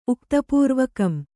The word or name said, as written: ಉಕ್ತಪೂರ್ವಕಂ